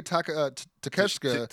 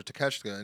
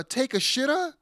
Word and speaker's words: Takeshita: Early high spot is where MJF comes in up before Danielson does, but obviously after Takeshita, and cuts a promo where he greets Konosuke with konnichiwa, buddy. Also made sure to mispronounce his name is and it's a take a shitta.